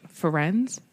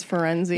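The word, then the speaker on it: Firenze: We meet a new centaur named Ferenz. – That's what I call him because that's how they say it. – Jim Dale says Ferenzi.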